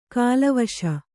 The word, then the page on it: ಕಿಲ್ವಿಷ: kilviṣa